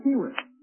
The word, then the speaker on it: Cherith: Almost all words in the Bible that begin with a CH are pronounced like a K. In other words, don't hesitate. Don't say, just say Kirith.